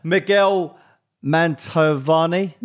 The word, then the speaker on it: Miguel Mantovani: I'll say that word better. Miguel Mantovani. – Yeah, that's it.